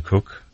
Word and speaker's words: Koch: We have Christina Cook, which it's pronounced Cook. It's spelled K-O-C-H, but it's actually pronounced Cook.